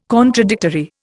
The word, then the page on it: contradictory: Indian